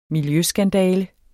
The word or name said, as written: miljøskandale